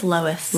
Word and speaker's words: Lois: And that's by Lewis Cam Heyman. – And I might have said that wrong. – I feel like it's Lois.